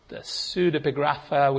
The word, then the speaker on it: Pseudepigrapha: Pseudepigrapha, which I never can pronounce quite rightly, but I think that's close, which are entirely apocalyptic.